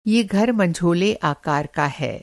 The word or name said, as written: मँझोला